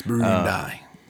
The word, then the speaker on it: Burundi: It's Burundi. – You actually got to say it like the Uruguay.